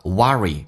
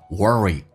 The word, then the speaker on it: worry: You worry，美式发音发重度卷舌，英式发音叫 worry，美式发音叫 worry，你在担忧。